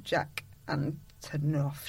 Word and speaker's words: Jack Antonoff: Her producer is Jack Antonoff, she said, reading it from a bit of paper.